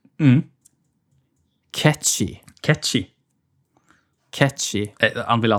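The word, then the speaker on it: Cait Sith: Og uttales da catchy. catchy. Men for meg og alle andre så er det jo Kate Sith.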